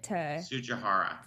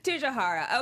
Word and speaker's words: Tsujihara: It's how do you pronounce it? – Tsujihara. – Okay.